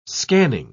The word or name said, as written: scanning